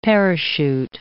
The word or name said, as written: parachute